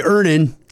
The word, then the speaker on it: Earnin: I'm going to pronounce this properly. Earnin. – Earnin. – E-A-R-N-I-N is pronounced Earnin.